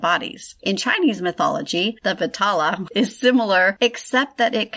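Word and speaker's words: Vetala: Stories of the Vitaha. No. – I must just say I'm enjoying the struggle with pronunciations. – I know, because it's usually you. – It's usually me on the other side. – It looks like it's Vitala. So,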